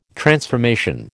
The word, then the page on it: transformation: American English